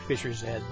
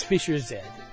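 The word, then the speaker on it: Fischer-Z: Could you believe it's actually Fisher Z, by the way? I said the American pronunciation, but it's Fisher Z, because it's like a fish head pun from England.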